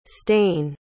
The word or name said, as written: stane